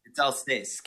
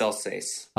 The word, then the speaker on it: Alsace: It's Alsace. Can you hear me? – Yes, I can. – Yes, it's Alsace. – Alsace. Where is Alsace? – Alsace, it's on the border of France.